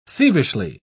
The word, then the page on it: thievishly: British English